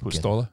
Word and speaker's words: Puistola: I say Puistola. I believe I'm wrong. – Patrick Darren, P. – Darren had the much better pronunciation. – Patty P. I think it's just like Puistola. It's very quick. – Pistola. Yeah. All right. Works for me.